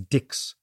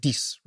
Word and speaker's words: Dix: So the English speakers looked at Dix and pronounced it phonetically as Dix, whereas the French pronounced it Dix, right?